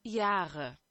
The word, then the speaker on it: Jahre: Jahre. – Jahre. Yeah, that's a proper, well, according to Google Translate anyway, that's a better pronunciation of Yara than I would have come up with.